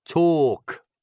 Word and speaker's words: talk: Allora, parlare in inglese, tu vedi che c'è un L, ma non si pronuncia l'L, è talk con pallone o talk, che vuol dire parlare.